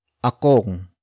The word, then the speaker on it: akong: The next word is akong. Akong. Akong. Akong is spelled as A-K-O-N-G.